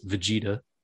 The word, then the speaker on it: Vegeta: Or the Vegeta, as they told me how to pronounce this, Vegeta.